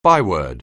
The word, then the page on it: byword: anglais